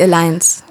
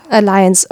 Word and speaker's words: Alliance: Und der NIG ist auch Mitglied in der Alliance of European Voluntary Service Organizations. Hoffentlich habe ich es jetzt richtig ausgesprochen. – Ja, die Alliance. – Alliance, oha, okay.